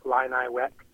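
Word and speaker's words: Illiniwek: This is in regards to the episode at the beginning of September where you were talking about the Illini and Chief Illini Wek. That's the pronunciation. You weren't butchering it, but it made me chuckle a little bit because who knows how to spell I-L-L or how to pronounce I-L-L-I. So, again, it's Illini and Illini Wek.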